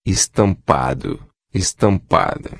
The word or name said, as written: estampado-a